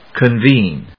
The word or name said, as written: Convene